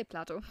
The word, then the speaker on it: Plato: I think, yeah, I mean, should be Plato. – Fine. – Yeah, it should be fine. We say Plato.